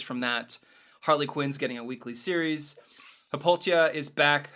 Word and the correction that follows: Hippolyta: How do you say her name? Wonder Woman's mom. – Hippolyta? – She just died, spoilers, in Trial of Amazons.